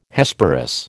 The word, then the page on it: Hesperus: American English